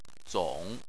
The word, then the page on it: zong: 3